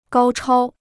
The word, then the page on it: 高超: gāo chāo